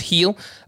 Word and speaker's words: Gil: Luis Gil, by the way, that's G-I-L is how it's spelled, but it's pronounced heel.